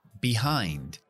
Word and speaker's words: behind: y BE, behind. Se acentúa en la segunda sílaba, pero la B es B. La pronunciación de la vocal es del abecedario.